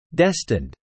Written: destined

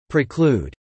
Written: preclude

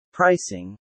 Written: pricing

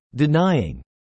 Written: denying

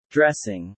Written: dressing

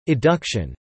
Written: eduction

